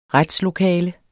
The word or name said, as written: retslokale